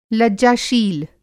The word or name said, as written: लज्जाशील